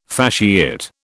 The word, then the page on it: fasciate: British English